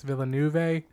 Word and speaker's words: Villeneuve: Dennis Villeneuve. – Villeneuve. Oh, yeah. – Villeneuve.